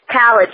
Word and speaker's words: Khaled: It's being hosted by Mike, and it'll feature plenty of celebrity judge appearances, including you know, DJ Khaled. I'm hoping I'm saying his name right, because Lord knows I hate butchering his name. – Yeah. Khaled, yes.